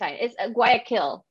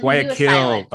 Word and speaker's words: Guayaquil: It's Guayaquil. – Like Guayaquil. Okay. Yeah. Guayaquil. That's important because it's going to come up again.